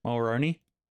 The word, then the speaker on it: Mulroney: Mulroney.